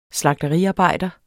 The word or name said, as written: slagteriarbejder